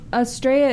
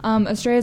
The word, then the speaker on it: Astrea: Well, Australia, it's spelled A-S-T-R-E-A, and my Instagram is A-A-S-T-R-E-A, if you were so inclined to follow me. Australia is the goddess of human justice in Greek mythology.